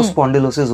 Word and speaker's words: spondylosis: Medical term जो होता है उसका, वो स्पॉन्डिलोसिस होता है. – Okay, so doctor, what is स्पॉन्डिलोसिस?